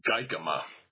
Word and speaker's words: Guikema: And there's a fellow named Grant Geikema, that's spelled G-U-I-K-E-M-A.